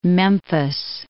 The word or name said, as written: Memphis